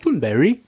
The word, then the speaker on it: Thunberg: Thunberry. Is that how it's pronounced? Thunberry. Thunberry. Yes. Ah, Good to know. Yes. Um, the Toonberry.